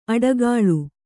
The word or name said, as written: ಅಟ್ಟುಗೂಳು